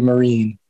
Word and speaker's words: Mirren: So I'll still fall back on Mirren sometimes, but I guess it's supposed to be Mirren. – Yeah.